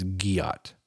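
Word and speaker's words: gyatt: They said it's a hard G, not J. So it's giot.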